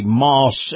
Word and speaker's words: Maus: And this portion of the program is brought to you by the Moss Nissan network of dealerships. Now, Moss is spelled M-A-U-S.